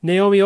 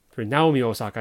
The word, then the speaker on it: Naomi: Naomi Osaka, Naomi Osaka, I guess, would be the Japanese pronunciation. But in America, she probably gets called Naomi because that's the American pronunciation.